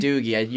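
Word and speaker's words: Doege: Yeah, West Virginia's quarterback, Jarrett Doge, or is that how – I think it's Doogie, maybe? – Doogie, you're right. I knew it was – it's something that's two syllables.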